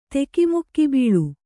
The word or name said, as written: ತೆಕಿಮುಕ್ಕಿಬೀಳು